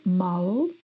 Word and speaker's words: mal: E quando você não está nada bem, você pode dizer que está mal, mal com L e o L pronunciado com a língua entre os dentes, mal.